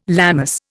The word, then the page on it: Lammas: Indian